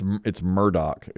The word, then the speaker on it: Murdaugh: Here's one I know how to pronounce. Alex Murdaugh. It's Murdaugh. It's not, it's spelled M-U-R-D-A-U-G-H. So but, I would say Murdaugh. And my wife's like, don't you dare.